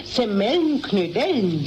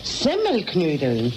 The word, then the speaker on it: Semmelknödeln: Jetzt hast du nämlich gerade gesagt Semmelknödeln. – Ist recht, Semmelknödeln. – Nein. Richtig muss es eigentlich heißen Semmelknödeln. Die Semmel muss man dabei betonen, weil doch die Knödel aus Semmel gemacht sind.